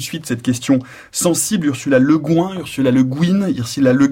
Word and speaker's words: Le Guin: Alors, normalement, elle préférait Le Guin, si j'ai bien tout compris, à la française, parce qu'elle a rencontré son mari à Paris.